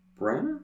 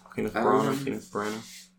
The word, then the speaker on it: Branagh: You might need to correct my pronunciation. Kenneth Branagh. – I think it's just I think that works. Kenneth Branagh.